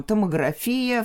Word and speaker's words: томография: Но вот у них всегда ударение ставится именно на этот корень. И несмотря на попытки попытки э, представителей медицинских разных профессий, да, медиков, раскачать это ударение. Оно только в их профессиональной сфере и используется таким, как э, там томография, флюорография, общеупотребительная, общеязыковая норма иная.